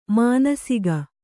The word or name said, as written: ಮಾನಸಿಕ